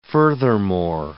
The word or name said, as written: furthermore